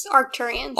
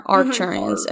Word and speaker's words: Arcturians: It definitely sounds, how do you say Arcturians? – Arcturians. – With an R, Arcturians, okay. I'll get it right.